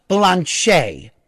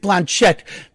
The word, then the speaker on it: Blanchet: And her character's name is Ruda Blanchet, but it is spelt Blanchette. And so what she constantly says is, when they call her Mrs. Blanchette, the T's are silent.